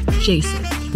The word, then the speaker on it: Jasek: Jasek.